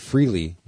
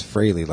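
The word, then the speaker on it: Frehley: and in case you guys don't know, Tommy Thayer, who is the new Replace Freely, or Fraley, I've always pronounced it Freely, but it's Fraley, like Haley's Comet, mm-hmm.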